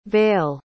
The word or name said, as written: vayle